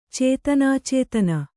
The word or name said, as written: ಚೇತನಾಚೇತನ